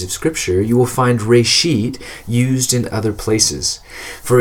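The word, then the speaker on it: reshit (רֵאשִׁית): But the Hebrew phrase being used here is actually a single word pronounced reshit.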